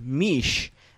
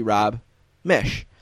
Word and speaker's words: Mish: We were calling him Mish, and I read in the book that his name rhymes with Wish. So sorry, Rob, it's actually Rob Mish.